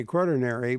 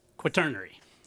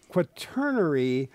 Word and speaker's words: quaternary: I want to say quaternary, but it's not. – Quaternary. – Quaternary geologists.